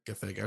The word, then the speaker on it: Gathegi: Superman Legacy adds Nathan Fillion as Green Lantern, plus Isabella Merced and Edie Gathegi. How do you say that? I think that's oh, right. Gathegi.